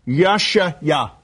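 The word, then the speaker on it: Yesha'ya: His name is Y-E-S-H-A, apostrophe Y-A. Yashaya.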